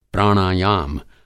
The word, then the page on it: प्राणायाम: Hindi